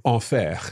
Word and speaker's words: enfer: Enfer, c'est l'enfer. – L'enfer. – So the word for hell as well. ER at the end, each time there's a little exception, but normally ER is pronounced as E.